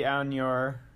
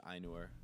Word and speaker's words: Ainur: So like, yeah, like you said, a lot of the Ainur, am I pronouncing that right? Ainur? – No. There's no Enya in it. It's just Ainur.